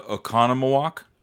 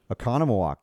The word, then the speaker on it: Oconomowoc: And how do you say it? We figured it out because it's not as many N-O's. – Oh, geez. It's a Oconomowoc. – Oconomowoc. You nailed it.